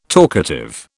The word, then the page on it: talkative: British English